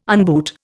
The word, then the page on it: unboot: Indian